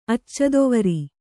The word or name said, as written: ಅಚ್ಚದೊವರಿ